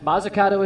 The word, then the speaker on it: Mazzucato: Tomorrow's starting pitching matchup, Grant Judkins against Frank Mazzucato. Did I pronounce Frank's last name right? – Mazzucato is correct.